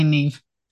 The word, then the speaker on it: Niamh: And Niamh. – Okay, That's thank that, you. Yeah, that is definitely Niamh.